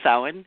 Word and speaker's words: Samhain: It's Samhain, which is spelled Samhain, but it's Samhain.